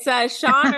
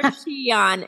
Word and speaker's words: Xian: It's Shawn or Shian, X-I-A-N, Shawn or Shian.